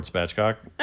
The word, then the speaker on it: Spatchcock: Man, this is Lord Spatchcock. I heard you say my name. Is that spelled right? S-P-A-T-C-H? – Yes.